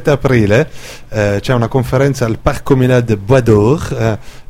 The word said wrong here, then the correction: Baudour: spero che lo pronuncio bene. – Secondo me è Baudour. – Baudour, ecco, merci ma chérie.